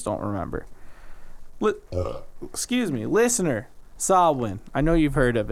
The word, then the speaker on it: Samhain: It's spelt Samhain, but it's pronounced Samhain, right?